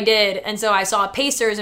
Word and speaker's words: Pacers: this girl pronounced Pacers, the basketball team. There is no K in Pacers. And she's like, yeah, the Packers.